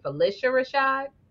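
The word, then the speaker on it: Phylicia Rashad: Okay, so this is how do you pronounce Felicia Rashad? Is it Felicia Rashad? – I pronounce that Felicia Rashad, but I'm always working on how I say things and I might not have it right. – No, Heffa, you got it right.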